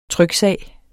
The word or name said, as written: tryksag